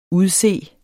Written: udse

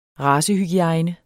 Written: RACEHYGIEJNE

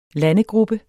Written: landegruppe

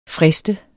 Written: frist

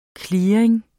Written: clearing

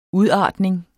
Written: udartning

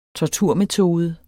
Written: torturmetode

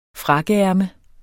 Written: frakkeærme

